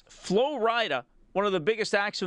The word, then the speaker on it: Flo Rida: That, as I found out this afternoon, is Flo Rida. It's spelt like Florida, except it's two words, and there's a capital R.